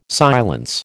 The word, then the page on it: silence: American English